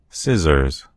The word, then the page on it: scissors: American English